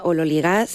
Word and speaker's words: ololigás: Teóricamente se pronuncia ololigás según la, bueno, pues la transcripción fonética del griego al español,